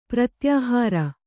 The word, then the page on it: ಪ್ರತ್ಯಾಹಾರ: pratyāhāra